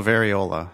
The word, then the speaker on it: variola: I don't know that word. – Let's go variola, huh? – Variola, thank you. You're much better at pronouncing than I am.